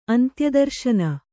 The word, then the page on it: ಅಂತ್ಯಾದರ್ಶನ: antyādarśana